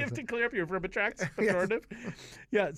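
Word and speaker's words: Vermithrax Perjorative: Vermithrax Pejorative.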